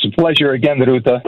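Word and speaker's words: Rūta: He happens to be of Lithuanian descent and therefore says Ruta. He rolls the R.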